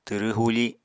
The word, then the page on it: twrw huufi: apatani